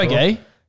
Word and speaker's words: Breguet: You're absolutely butchering these names to a degree that I've never seen before. Breguet.